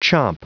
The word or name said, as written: chomp